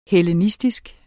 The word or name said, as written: hellenistisk